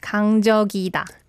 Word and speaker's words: 강적이다: Yeah, so you need to kind of transfer the ending sound to the next 이응. – Mm-hmm. – So it's 강적이다.